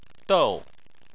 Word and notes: dou: 4

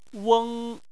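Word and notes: weng: weng1; tone 1

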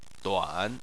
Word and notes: duan: 3

